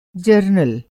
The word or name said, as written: जरनल